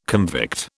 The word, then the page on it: convict: British English